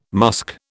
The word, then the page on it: musk: British English